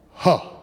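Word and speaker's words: huh (ה): When you study Hebrew, there's a Hebrew letter that is our English transliteration is H-E-Y. Well, you don't pronounce it H-E-Y. Hey, it's pronounced huh.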